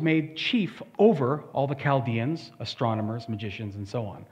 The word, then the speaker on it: Chaldeans: They're known as Chaldeans, that's how it's pronounced.